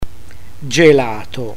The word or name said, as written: gelato